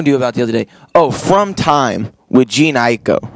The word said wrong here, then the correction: Jhené Aiko: Jhené Aiko? Is that how you say it? – Mm-hmm.